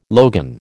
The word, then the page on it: logan: American English